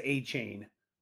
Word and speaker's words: Achane: The Dolphins told us that we needed to pronounce it as A-Chain.